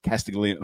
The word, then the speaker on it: Castiglione: But it was odd to me that he didn't use first names and athletics director Castiglione. Like, just too formal for me. – That's how you say it.